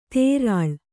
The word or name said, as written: ತೆರಳ್